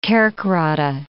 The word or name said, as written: Kerkrade